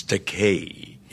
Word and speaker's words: Takei: He corrected the pronunciation of his name, but he then called me George Takei, a common mispronunciation of my surname because of the EI. They want to give it that Germanic Einstein, you know, pronunciation, mm-hmm. but it's the vowels in Japanese are pronounced like the Mediterranean languages, Italian, Spanish. It's Takei, E-I. However, there is a Japanese word pronounced Takai with an A, T-A-K-A-I, and that translates into English as expensive.